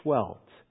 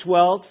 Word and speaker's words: Seheult: His name is Dr. Roger Schwelt. His name is difficult to spell. It's spelled S-E-H-E-U-L-T. Dr. Schwelt, for years, has been teaching health professionals medical topics.